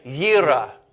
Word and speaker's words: Yireh: Say Yahweh Yireh. Yireh. It's fun to roll your R's too.